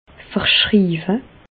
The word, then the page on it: verschriiwe: Bas-Rhin; Reichshoffen